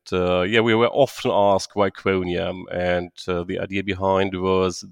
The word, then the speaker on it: Quoniam: Did I pronounce Quoniam right? Is it Quoniam? – Yes. – Quoniam? – Perfect pronunciation, yeah. – It's Quoniam, excellent.